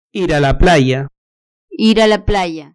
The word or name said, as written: ir a la playa